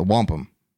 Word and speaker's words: wampum: But there is like, we, you know, all jokes aside, with the Wanup belt, is it the Wanup belt? How do I say it again? – The wampum.